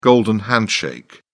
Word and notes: golden handshake: British English

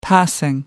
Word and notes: passing: American English

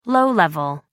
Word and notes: low-level: British English

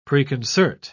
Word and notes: preconcert: British English

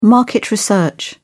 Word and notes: market research: British English

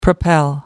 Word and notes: propel: American English

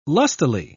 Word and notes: lustily: British English

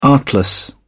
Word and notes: artless: British English

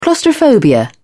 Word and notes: claustrophobia: British English

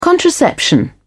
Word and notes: contraception: British English

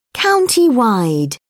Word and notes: countywide: British English

